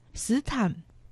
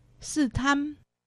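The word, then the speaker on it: 獅潭: 那里就是斯坛，苗栗县斯坛乡，客家话四线乡发音斯坦，海陆腔发音斯坛。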